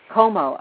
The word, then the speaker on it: Como: Como, okay. – That's it, yeah. A lot of people say Como, and it's just Como, as if you were, you know, C-O-M-O.